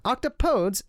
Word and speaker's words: octopodes: Octopodes, it should be noted, takes a slightly different pronunciation than the other two words, placing an emphasis on the second syllable rather than the first. It more or less rhymes appropriately enough with don't say that, please.